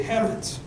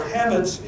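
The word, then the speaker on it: chametz: Leaven in Hebrew is, and I probably don't have the pronunciation correct, it's called shemetz, or pronounced actually hemetz. And this instruction to remove the yeast or hametz is taken quite seriously.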